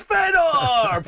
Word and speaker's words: Fedor: His name is Fedor. Pronounced a Fedor.